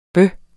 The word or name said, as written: bøh